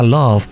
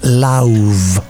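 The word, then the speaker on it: Lauv: Vamos a arrancar con el corte titulado I Like Me Better, con este vocalista, compositor, que mucha gente le pronuncia love, pero es love.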